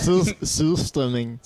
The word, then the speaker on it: surströmming: So you're probably not the guy who would like to eat surströmming. Did you say it correctly? – You said what? – Say what? – Surströmming. – Surströmming.